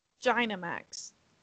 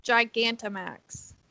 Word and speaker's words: Gigantamax: Gynamax. Gintamax. You'll you'll That's say it correctly it. soon. I, I have faith in you. – Gigantamax.